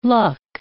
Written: luck

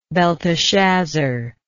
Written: belteshazzar

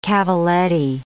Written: cavaletti

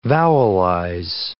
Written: vowelize

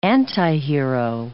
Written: antihero

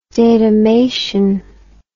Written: datamation

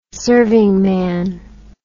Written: servingman

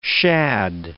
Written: shad

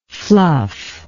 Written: fluffer

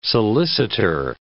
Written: solicitor

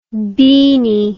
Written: beany

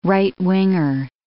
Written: right-winger

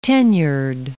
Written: tenured

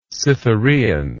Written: cytherean

